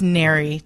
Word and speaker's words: NARI: Or am I saying it right? NARI? – It's NARI, technically.